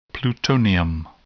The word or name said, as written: plutonium